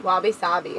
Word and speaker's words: wabi-sabi: Wabi-sabi. – Wabi-sabi.